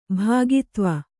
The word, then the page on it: ಭೋಗಿತ್ವ: bhōgtva